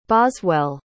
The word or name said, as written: boswell